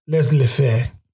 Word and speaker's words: laissez-faire: You got laissez-faire. I was saying it right in French.